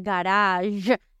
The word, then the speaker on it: garage: En inglés no se dice garaje, se dice garage.